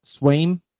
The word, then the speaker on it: Swaim: Am I saying this right? Jess Swaim, S-W-A-I-M. – Swaim? – Swaim, yeah.